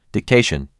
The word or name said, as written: Dictation